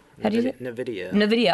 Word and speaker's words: NVIDIA: Another one from The Verge, where, and I'm going to say this wrong, I'm sure, NVIDIA? – NVIDIA.